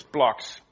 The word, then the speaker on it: BLOX: It's BLOX, B-L-O-X, BLOX. And it's spelled with capital letters. Very modern, very designed. And of course, it's because it looks a bit like blocks.